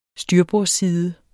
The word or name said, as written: styrbordsside